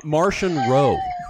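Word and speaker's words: Marchion Ro: How do you say the leader of the Nihil? – Martian Rowe. – Oh. – Like Martian the Manhunter? – Martian.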